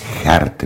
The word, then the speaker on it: Geert: Geert Wilders. – Geert. – Čte se to Geert? – No tak určitě nizozemsky neboli holandsky se to ani jinak nemůže říkat.